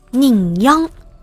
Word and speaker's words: 人羊: 奶奶说：“ 那是宁央。” 啊，我学的不太像啊，但是投稿的小伙伴说用客家话音调就是这么发音的。他说：“ 我奶奶没读过书，也不知道这两个字怎么写。如果翻译成普通话的话，就是人羊。”